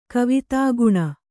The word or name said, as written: ಕವಿತಾಗುಣ